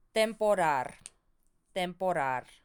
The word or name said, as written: temporar